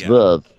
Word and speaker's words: Veuve: The thing is, the way that Veuve Clicquot is pronounced, it's pronounced the way you would expect Toya would mispronounce something else, like Veuve, like if you were to say, like, I don't know, like, here's my Aunt Vivian, she'd be like, here's Aunt Veuve, you know? It's Veuve, but she says Veuve like Veuve.